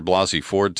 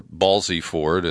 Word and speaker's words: Blasey Ford: Now, he did allow that he could change his mind after hearing the testimony from Dr. Blasey Ford today, or Balsey Ford, as Rush Limbaugh intentionally mispronounces her name.